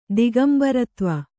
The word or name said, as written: ದಿಗಂಬರತ್ವ